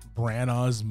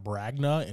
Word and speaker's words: Branagh: It's not Bragnah, it's Branagh. I'm going to pronounce that right one of these days. – Yeah, the G is definitely not there in the middle.